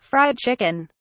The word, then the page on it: fried chicken: British English